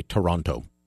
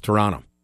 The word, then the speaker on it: Toronto: Man, I hate it when I pronounce that second T. Fortress Toronto. Just comes out sometimes. I say Toronto. As if I'm from somewhere else in Canada. You people in Toronto. Nobody near it pronounces it that way. It's Toronto.